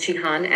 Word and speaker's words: Jihane: Yes, my name is Jahane Eater, and that's spelled J-I-H-A-N-E, and my last name is E-T-E-R.